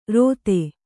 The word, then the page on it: ರೋತೆ: rōte